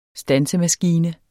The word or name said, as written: stansemaskine